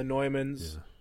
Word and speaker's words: Neumann: You're speaking of the Neumann. Is that how you say it? – Yeah, the Neumanns.